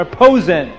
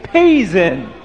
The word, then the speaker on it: Posen: P-O-S-E-N. How do you spell, how do you pronounce that? Posen? It depends who you are. Some people would say posen. Some people would say poison. And some people would say peizen.